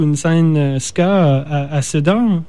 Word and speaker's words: Sedan: Et vous êtes, parce que j'ai fait de mon mieux pour faire de la recherche, vous êtes, le groupe vient de, corrige-moi si je prononce mal le nom, de Sedan, la ville? – Tout à fait, Sedan, oui, Sedan.